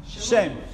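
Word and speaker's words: Shem (שם): Shem.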